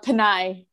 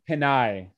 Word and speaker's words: Pinay: Did I pronounce the female version correctly? – It's a Pinay. – Pinay. Thank you so much.